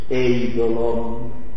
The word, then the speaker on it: eidolon: Voi sapete forse, è una cosa molto interessante, che la parola idolo viene da una parola greca che si pronuncia quasi ugualmente eidolon.